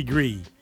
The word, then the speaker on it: Gris: Gree Gree, thank you for the pronunciation there, Mr. Matt, because I would have said gris. So I definitely would have fucked that up.